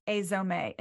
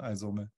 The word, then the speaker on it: AIZOME: Okay, so first question, why did you start AIZOME? And did I say that correct? – You said it correct, yeah. Awesome. Maybe AIZOME is a Japanese pronunciation, AIZOME.